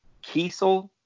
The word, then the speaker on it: Kesel: It's got a very cool cover from Stephen DeStefano and Karl Kesel, is how I was told to pronounce it. I had always said Kessel before.